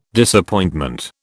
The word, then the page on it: Disappointment: British English